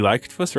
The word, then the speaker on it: Leichtfuss: Okay, so the next one was Amy Leichtfuss, right? – Yeah, good pronunciation, man. Well done.